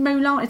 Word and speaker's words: Mulan: Have you seen Mulan? – That is how you say it.